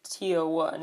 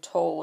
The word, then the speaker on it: TO1: They'll pronounce it TO1 instead of Toll or something.